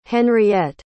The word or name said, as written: Henriette